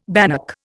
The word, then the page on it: bannock: Indian